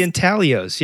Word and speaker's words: Intaglios: No, it's like these figurines out in Blythe called the Blythe Intaglow. I don't even know if I'm saying this right. Blythe Intaglows. And they're the Intaglios. Yeah. – Is that what it is? It probably is. See, I'm so. – It's an Italian word.